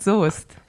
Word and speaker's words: Soest: Genauso, man schreibt Soest, aber man sagt Soest.